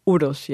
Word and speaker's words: Uros: Uros? – Uros, yes.